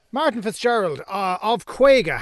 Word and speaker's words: Kwayga: Now, Kwayga, I'm presuming that is a play on the Irish word for 50, even though it's spelled K-W-A-Y-G-A. – Yes, Kwayga. It is indeed.